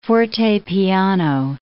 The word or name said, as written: forte-piano